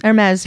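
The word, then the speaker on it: Hermès: That's how you say it, isn't it? Hermes? – Yes. Hermes, yeah.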